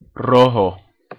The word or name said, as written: rojo